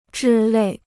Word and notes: 之类: zhī lèi

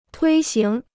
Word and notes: 推行: tuī xíng

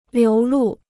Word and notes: 流露: liú lù

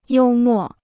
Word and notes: 幽默: yōu mò

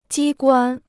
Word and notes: 机关: jī guān

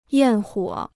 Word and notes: 焰火: yàn huǒ

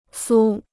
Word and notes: 酥: sū

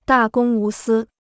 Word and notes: 大公无私: dà gōng wú sī

